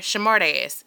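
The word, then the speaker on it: Shamartess: Yeah, it's spelled S-H-A-M-A-R. Shamartis. T-E-S-S.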